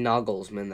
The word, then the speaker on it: Nagelsmann: They thought things were going to change with the firing of Nagelsmann, but I mean, Nagelsmann, that's the correct pronunciation.